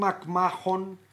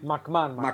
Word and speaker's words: McMahon: Καπάκι βγαίνει το ραπόρτο από τον Tim McMahon. Και λέει Porzingis and... – McMahon. McMahon προφέρει.